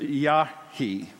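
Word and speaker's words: Yarchi: but whose name is properly pronounced Yahi.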